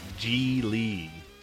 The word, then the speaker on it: Gigli: That's O-V-N-I-O. – Now, time for the podcast. – Gigli, or as the pronunciation on Wikipedia, Gigli, does not have rhymes with really.